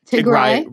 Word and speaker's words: Tigray: about the Tigran. Is that right? Is that how you say it? – Tigray region.